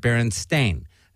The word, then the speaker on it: Berenstain: No, you could go get your own personal book from when you were a little kid and look at it, and it's going to say S-T-A-I-N. Now, did you mispronounce it? Not possible. Why is it not possible? Because every kid today pronounces it Berenstain. They know how to pronounce it correctly.